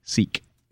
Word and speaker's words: sic: And it's pronounced seek.